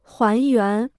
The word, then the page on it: 还原: huán yuán